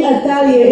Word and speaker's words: Athaliah: Her name is spelled A-T-H-A-L-I-A-H, Athaliah.